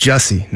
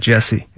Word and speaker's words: Jussie: And it's pronounced Jussie, not Jesse. – It's Jussie with a U.